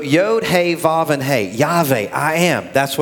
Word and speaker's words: Yahweh: You see, Yahweh, and I'm not mispronouncing that, in the Hebrew, it's the Yod, He, Vav, and He.